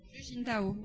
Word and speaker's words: Hu Jintao: Hu Jintao, voilà.